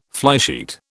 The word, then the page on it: flysheet: British English